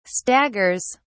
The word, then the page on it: staggers: British English